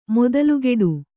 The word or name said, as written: ಮೊದಲ್ಗಿಡು